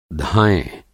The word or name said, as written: धायँ